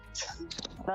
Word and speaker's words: Weishaupt: Weishaupt.